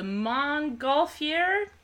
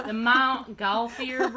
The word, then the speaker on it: Montgolfier: So the Montgolfier, that's French, I don't fucking know how to say it, Montgolfier brothers, the Montgolfier brothers. – I think you're nailing it.